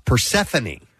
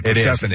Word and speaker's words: Persephone: Persephone. – That's tough for people to say, Persephone.